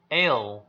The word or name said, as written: ail